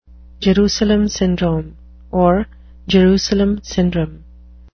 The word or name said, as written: Jerusalem syndrome